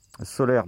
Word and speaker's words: Solayr: Solaire, donc S-O-L-A-Y-R.